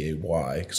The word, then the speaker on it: Blazay: And then I changed my at name to Jay Blaze, but I spelled it Blazay so you could pronounce it because you can't do accents on Instagram.